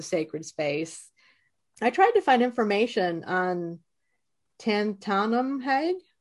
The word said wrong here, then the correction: Tantamahag: Is that how you say his name? – Well, Tantamahag. Tantamahag.